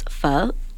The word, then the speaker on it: pho: So it's called pho. – Pho? – It sounds like a question, right? So it's not pho, it's pho.